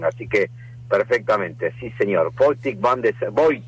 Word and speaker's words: Botik van de Zandschulp: Botik van de Zandschulp.